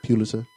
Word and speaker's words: Pulitzer: You see how I pronounce that Pulitzer? Not P- Pulitzer Prize for music.